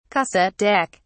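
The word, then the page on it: cassette recorder: British English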